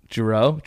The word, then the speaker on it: Giroux: Giroux. – It's spelled like Matt, like G-I-R-O-U-X.